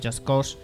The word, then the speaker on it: Just Cause: los Just Cause o Just Cause o como se diga. Lo dije bien la primera.